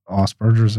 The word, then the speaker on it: Asperger's: if you wanted to know all my titles, dyslexic, dyspraxic, dyscalculia, touch of Asperger's, how do you pronounce it? – Asperger's.